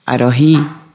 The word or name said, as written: limpiar